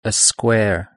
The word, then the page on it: a square: British English